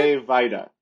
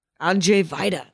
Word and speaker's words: Andrzej Wajda: And then Wajda is W-A-J-D-A? – That's correct. That is correct. – And it's pronounced Andrzej Wajda? Is that how you pronounce it? – Andrzej Wajda. – Andrzej Wajda. There we go.